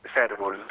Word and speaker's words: Cérvol: Cérvol, Cérvol.